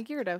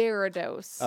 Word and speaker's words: Gyarados: And am I saying that right? – Gyarados? Yeah, Gyarados. – Yeah. And so I remember getting in my jammies. – Some people might in the car. – Say Gyarados.